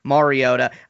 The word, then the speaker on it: Mariota: saying Mariota instead of Mariota. – I have to put some respect on his name. – Right. It's still something that it's just a plague league-wide. No one knows how to say his name. It's turned into an Alvin Kamara situation where it's just acceptable both ways. But yes, Mariota.